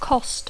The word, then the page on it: cost: English